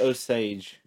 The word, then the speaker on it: Osage: It is about the reign of terror of the, I want to say Osage Nation. Is that the correct pronunciation? – They say Osage.